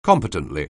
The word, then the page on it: competently: anglais